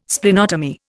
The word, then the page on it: Splenotomy: Indian